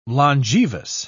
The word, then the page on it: longevous: British English